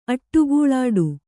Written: ಅಡಕಲಿಡು

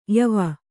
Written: ಯಾವ

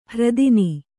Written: ಹೆರತನ

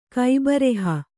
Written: ಕೈಬರಹ

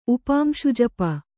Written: ಉಪಾಂಶುಜಪ

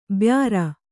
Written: ಬ್ಯಾರ

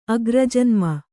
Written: ಅಗ್ರಜನ್ಮ

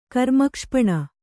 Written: ಕರ್ಮಕ್ಷಪಣ

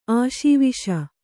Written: ಆಶಾವೇಶ

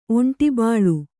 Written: ಒಂಟಿಬಾಳು

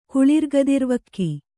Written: ಕುಳಿರ್ಗದಿರ್ವಕ್ಕಿ

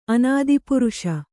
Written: ಅನಾದಿಪುರುಷ

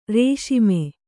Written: ರಾಸ್ಮೆ

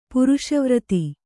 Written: ಪುರುಷವ್ರತಿ

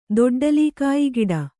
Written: ದೊಡ್ಡಲೀಕಾಯಿಗಿಡ

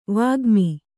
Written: ವಾಗ್ಮಿ

